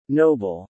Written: noble

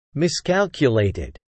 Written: miscalculated

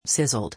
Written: sizzled

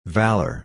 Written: valour

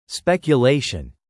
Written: speculation

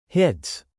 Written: hits

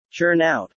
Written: churn out

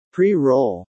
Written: pre-roll

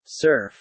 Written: surf

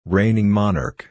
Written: reigning monarch

